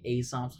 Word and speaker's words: Aesop: Also, just real quick, the funny thing about saying Aesop's Fables is the fact that it's not actually pronounced A, it's actually Aesop, so the joke doesn't actually work.